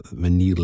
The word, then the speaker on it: vanille: Although when David pronounces it in his French accent, so vanille leather is much more attractive and sounds much nicer than my sort of clunky English version of it.